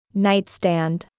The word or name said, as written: Nightstand